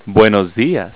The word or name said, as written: buen dia